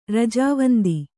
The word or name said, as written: ರಜಾವಂದಿ